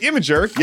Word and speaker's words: Imgur: Well, now image hosting site Imgur, yes, that's how it's pronounced, is doing the same thing.